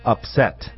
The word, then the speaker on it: upset: 要纠正大家，不是 upset, 是 upset.、嗯、 upset. – 注意是 upset. – Yeah, 注意在后面。